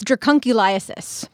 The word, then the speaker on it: Dracunculiasis: I think it's Draculiosis. – That's what it sounds like. It's Dracunculiasis.